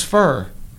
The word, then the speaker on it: Fuhr: F-U-H-R. – It was fur. – Yep. Like, you get it? – Mm-hmm. It was just spelled F-U-H-R. It's like, how's that pronounced? Well, how do you spell it? F-U-H-R.